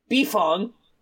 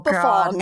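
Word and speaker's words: Beifong: And you, I ask this question, Toph Beefong, will you be my wife? – Yes!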